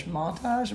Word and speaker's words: montage: Montage? Montage. – Say it the French way.